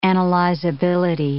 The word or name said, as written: analyzability